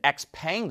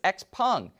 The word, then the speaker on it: XPeng: Chinese automaker XPeng, we've been pronouncing it XPeng this whole time, but apparently it's XPeng.